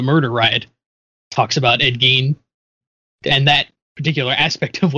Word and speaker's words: Gein: Ed Gein? – Yep.